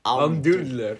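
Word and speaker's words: Almdudler: Pronounce that word right there. – Almdoodler. – Almdoodler.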